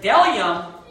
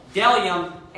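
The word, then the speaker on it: bdellium: Then it says, there is delium and onyx stone. So that B is silent.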